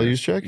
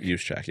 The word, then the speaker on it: Juszczyk: For the Kyle Juszczyk? – Juszczyk, yes. – Okay. A J-U. – That second one, yeah. – Why do you always do Kyle Juszczyk? – I never do Kyle Juszczyk.